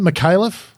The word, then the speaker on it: Micallef: McAuliffe. – People. – That's Sean, how he pronounces his own name. Do you get... – And? – McAuliffe. Right. Okay. Sean have you Micallef.